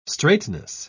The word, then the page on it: straitness: British English